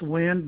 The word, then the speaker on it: Wynd: Second word is pronounced wind, but it's spelled WYND.